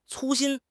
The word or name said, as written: cu1 xin1